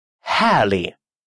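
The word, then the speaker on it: härlig: Хэли, чудесный, хэли. Обратите внимание, что буковка «г», стоящая после буквы «и», не читается.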